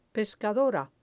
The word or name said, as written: pescadora